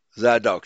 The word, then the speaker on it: Zadok: Let's see how they pronounce this. Zadok. They put the emphasis on the second syllable.